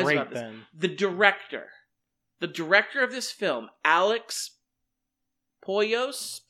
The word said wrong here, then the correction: Proyas: I don't know if I'm pronouncing his name wrong. This is what I was so shocked about. And what Proyas? Proyas.